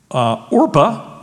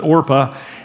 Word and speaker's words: Orpah: And one of the daughters' name was Orpah, by the way. And Oprah gets her name from Orpah because somebody mispronounced it, I think Oprah said. And we ended up calling her Oprah, interestingly, but Orpah.